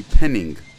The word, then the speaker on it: penning: There's also the Swedish penny, which is called a penning, with an emphasis on pronouncing the letter G at the end of the word.